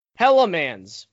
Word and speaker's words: Hellmann's: Hellmann's real mayonnaise. – This Hellemans. is an American company. – I think it's yeah. pronounced Hellmann's.